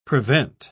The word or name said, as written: prevent